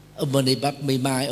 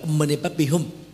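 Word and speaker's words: Om Mani Padme Hum: Là bà không có đọc đúng cái ngữ âm Sanskrit, người ta là Ummi Padme Hum, mà ông bà đọc là Ummi Padme Mai hay là Ummi Padme gì đó, đại khái vậy.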